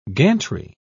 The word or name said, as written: gantry